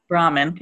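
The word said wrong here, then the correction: Bhaerman: thank you. – Berman.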